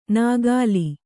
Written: ನಕಲಿ

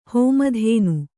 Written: ಹೋಮಧೇನು

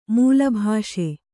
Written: ಮೇಲುಬ್ಬಸ

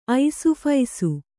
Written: ಐಸುಫೈಸು

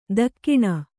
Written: ತಗುಣೆ

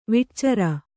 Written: ವಿಟ್ಚರ

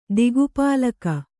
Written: ದಿಕ್ಪಾಲಕ

